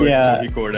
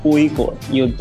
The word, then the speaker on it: Kozhikode: Even I am not like 100% correct, but what I've learned is Kozhikode. The Z is silent. So Kozhikode.